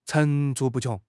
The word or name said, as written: 层出不穷